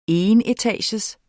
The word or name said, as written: enetages